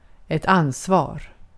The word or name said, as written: ansvar